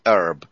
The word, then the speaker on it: herb: My linguistic advisor just told me that some Americans do say herbs instead of just herb, but most of them say herb, which is the French pronunciation of herbs.